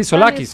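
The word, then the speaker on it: Tzolakis: Es Tosolakis, ¿no? Sí, Solakis. – Solakis, porque la T es Solakis.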